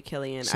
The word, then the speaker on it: Cillian: I do like Cillian Murphy. – Cillian? – No, it's literally Cillian.